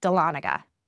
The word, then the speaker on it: Dahlonega: This week we are sharing at Sugar Pine Cabin Dahlonega. I can never say that right. Sugar Pine Cabin Dahlonega.